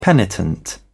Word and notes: penitent: British English

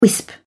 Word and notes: wisp: British English

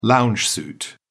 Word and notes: lounge suit: British English

